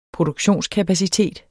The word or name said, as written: produktionskapacitet